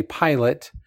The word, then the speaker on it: Pilote: That's the French version of how we would say pilot, but it's pilot, I've learned.